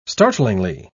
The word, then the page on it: startlingly: British English